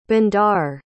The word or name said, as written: Bondar